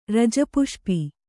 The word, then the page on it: ರಜಪುಷ್ಪಿ: Kannada